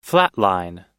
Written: flatline

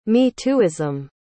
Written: me-tooism

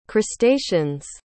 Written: crustacean's

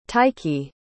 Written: taiki

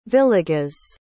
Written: villegas

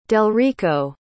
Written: delrico

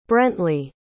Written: brentley

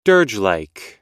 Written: dirgelike

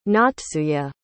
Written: natsuye

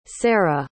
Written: sarah

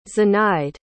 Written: zenaide

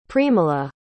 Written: premala

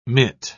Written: mitt

